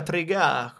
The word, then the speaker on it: triggered: Si pronuncia triga, come dice Mark Norman.